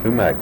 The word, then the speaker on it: sumac: Did you ever hear of sumac or shumac? – Yeah, I've heard that word, but I wouldn't know what one looked like. – How would you pronounce it? – Sumac bush.